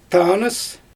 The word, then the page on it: taanɯs: Xyzyl